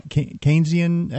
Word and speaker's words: Keynesian: Yeah, you know, I think what we're seeing is really the end game of uh, uh, Keynes, Keynesian economics.